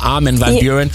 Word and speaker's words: Armin van Buuren: Ich habe irgendwann mal Armin gefragt und er so, sag es im Englischen, dann ist es viel, viel einfacher. Armin van Buuren. Deswegen sagen wir Armand van Helden.